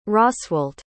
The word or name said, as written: Roswalt